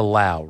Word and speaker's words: Lau: So remember, this is the Lau, rhymes with now.